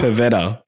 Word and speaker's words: Pavetta: Did you say Pavella? Did you just say Pavella? Yeah, I said it wrong. – Yeah, come on, man. – What's her name? – Pavetta.